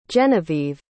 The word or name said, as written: genevive